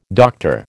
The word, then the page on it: Dr: American English